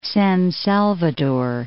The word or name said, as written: San Salvador